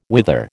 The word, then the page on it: whither: American English